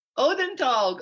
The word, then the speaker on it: Odenthal: Odenthal.